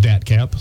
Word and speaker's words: DATCP: We pronounce it DATCAP.